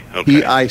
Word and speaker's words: Picoult: Picoult. The T is silent. – Right, that Jaws pronounces pickled or something.